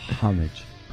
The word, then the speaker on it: homage: Homage. – Homage. Is it homage? Is it homage? – Homage. – Is it? So the H is silent? – The H is definitely silent.